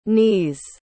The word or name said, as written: neese